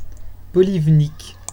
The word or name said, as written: polivnyk